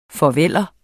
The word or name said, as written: farveller